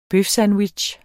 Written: bøfsandwich

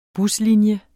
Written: buslinje

